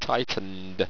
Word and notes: tytnnd: owstwecian

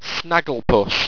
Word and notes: snagglepus: owstwecian

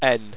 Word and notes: en: Owstwecian

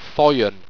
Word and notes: thoien: owstwecian

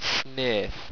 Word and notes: snaeth: owstwecian

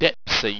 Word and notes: depsze: Owstwecian

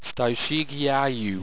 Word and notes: styshegeiieu: owstwecian

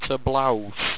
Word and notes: toblous: owstwecian

